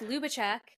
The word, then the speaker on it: Ljubicich: It's pronounced Lou, funny enough. – Is it uh, actually? – Yes, my last name is Ljubicich.